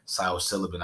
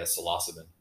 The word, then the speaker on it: psilocybin: I mean, there's been studies with psilocybin. I, I don't know if I ever pronounced it right. How? – Yeah, it's uh, either yeah psilocybin.